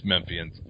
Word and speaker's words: Memphians: And, I mean, to me, one thing that was noted by some of the Memphians, is that how you say it, Memphian? – No idea. Memphisans? – No, I think it is Memphians.